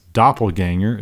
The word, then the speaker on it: doppelganger: Our first word is doppelganger. It is spelled D-O-P-P-E-L-G-A-N-G-E-R. I believe it's German, doppelganger. D-O-P-P-E-L-G-A-N-G-E-R, doppelganger.